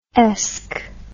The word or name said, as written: esque